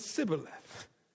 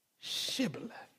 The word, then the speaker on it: Shibboleth: It wasn't Sibboleth. It was Shibboleth.